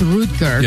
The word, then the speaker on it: Rutger: The first time you said Rutger. – It's everybody Rutger. Said Rutger, but I believe in German it's Rutger.